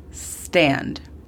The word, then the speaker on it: stand: La pronunciación fonética de este sería S-E-T, sit. El segundo verbo es stand. Stand. Muy importante recordar con este verbo que no decimos la E antes de la S. Se comienza desde la pronunciación de la S. Stand. Este significa pararse o ponerse de pie. La pronunciación fonética de este sería S-T-E-N-D, stand.